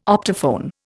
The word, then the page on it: optophone: Indian